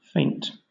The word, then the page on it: feint: British English